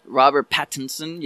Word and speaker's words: Pattinson: Am I saying that right? – Robert Pattinson, yeah.